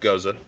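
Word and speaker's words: Gose: So for people who don't know what a Goza is, it's pronounced Goza.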